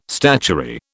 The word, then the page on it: statuary: British English